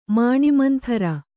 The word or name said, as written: ಮಾಣಿಮಂಥರ